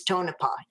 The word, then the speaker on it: Tonopah: Okay, but before I do that, I don't want to forget, you did pronounce Tonopah correctly when you, it is Tonopah.